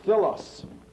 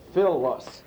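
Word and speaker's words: Philos (φίλος): The Greek word Philos, which means accents on the first syllable, Philos. The phi here is an F.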